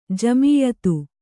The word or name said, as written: ಜಮಿಯಿತ್ತು